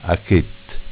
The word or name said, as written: Achett